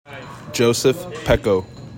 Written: JOSEPH PEKO

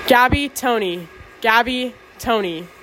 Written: Gabi Toney

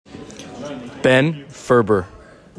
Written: BEN FERBER

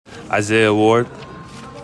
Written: ISAIAH WARD

